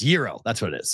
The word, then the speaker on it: gyro: And hero is not right because there's no H in it. So it's gyro. The G is silent.